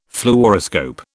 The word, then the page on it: Fluoroscope: British English